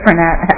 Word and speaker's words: Frenette: I'm Lisa Frenette, Effison Frank, R-E-N-E-T-T-E.